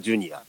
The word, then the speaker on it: JR: ージュニアはいはいはいとね J と R と書いてジュニアなんですけど